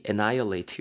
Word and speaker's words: annihilate: A N N I H I L A T annihilate 이렇게 발음하시면 되는데요. 완전히 그냥 무언가를 전멸시키다.